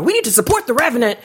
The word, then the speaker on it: Revenant: Well, you were starting to talk about, I can never say resident, how you say it? – The Revenant. – The Revenant. – The Revenant. – I always want to call it resident.